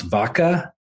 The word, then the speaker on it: Vacha: It's V-A-C-H-A, sometimes pronounced Vaca, sometimes pronounced Vacha.